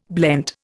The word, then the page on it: blent: Indian